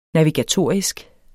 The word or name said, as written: navigatorisk